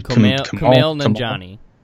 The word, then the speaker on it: Nanjiani: Kumail Nanjiani. – Yeah, Kumail Nanjiani.